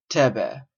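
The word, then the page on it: thebe: Canadian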